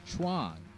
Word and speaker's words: Chuang: And also doing this episode, Jamie and I learned, I was saying Chuang all this time. It's actually pronounced Chuang, which rhymes with bong.